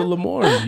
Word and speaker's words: Lamorne: He's listening to Lamorne.